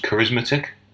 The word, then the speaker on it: charismatic: It's pronounced charismatic. – It's like arithmetic.